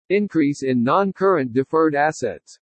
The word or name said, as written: INCREASE IN NON-CURRENT DEFERRED ASSETS